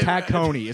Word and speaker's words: Tacony: Exactly, it's actually Tacony. It's pronounced Tacony is how it's pronounced.